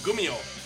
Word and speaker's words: Gumiho: It's G-M-I. G-U-M-I-H-O. – Oh, Gumiho. – Yes. Gumiho. But they say it's a KG sound at the beginning, so I'm not sure how to do that.